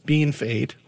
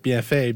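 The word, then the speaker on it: Bienfait: and they're shocked that it's not pronounced BFA because that's what it looks like.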